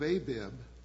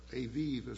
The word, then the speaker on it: Aviv: It says, observe the month of Abib, or Aviv, as some people pronounce it, the correct pronunciation, by the way, according to Hebrews,